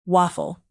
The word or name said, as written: waffle